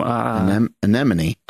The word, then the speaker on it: anemone: Anemone. – Anemone, yeah. That's anemone. Good. From anemone. Am I saying that right? – Yeah, you're anemone? Saying it like Nemo.